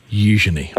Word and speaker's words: Eugenie: Eugenie. – At least we got the name right. Heavy on the U, and quick on the Je. Me. – Okay. Eugenie.